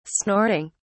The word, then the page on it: snorting: English; British English